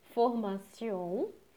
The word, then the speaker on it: formation: Mas no francês, todas as palavras que possuem o final em T-I-O-N são pronunciadas como se fossem C. Por exemplo, formation, que a gente fala em português, se lê formation.